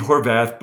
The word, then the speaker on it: Horvath: I think it's called the Havarth clock. Is that pronunciation correct that you're mentioning? – Yeah. So I think it was Steve Horvath, but regardless of the pronunciation, that's exactly it.